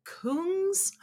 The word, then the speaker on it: Kungs: This song is a collaboration between French house artist Kings. It's pronounced Kungs and Melbourne, Australian funk trio Cooking on Three Burners.